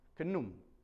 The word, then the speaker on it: Khnum: And that's spelled K-H-N-U-M. Kenum.